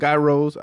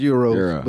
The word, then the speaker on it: gyros: But I like, you know what I'm saying, gyros. I know you don't supposed to say it like that, but in Detroit, that's how we say it. We say gyros. – Is it Euro? – Yeah, it's Euros.